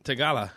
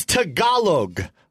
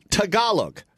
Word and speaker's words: Tagalog: Tagala. – Okay. I've taught how to say the language 95 times. I think you are choosing to say it wrong because it's funnier. It's Tagalog. Tagalog.